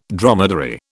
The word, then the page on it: dromedary: British English